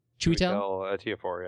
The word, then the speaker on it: Ejiofor: I felt very, it was that same experience the first time I saw, I need the pronunciation police, Chiwetel Ejiofor? Chiwetel? – Ejiofor, yeah.